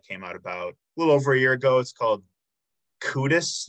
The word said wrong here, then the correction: Cooties: Did I say that right? – It's actually Cooties in Latin. – Cooties, excuse me.